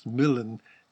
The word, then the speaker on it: Milan: It's pronounced Milan in The Tempest.